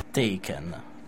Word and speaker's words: Taken: Comunque, io direi di andare ad ascoltare Token. Detto male, però non importa, perché loro le parole le pronunciano bene più di me, anche perché sono madrelingua. Taken, One Direction.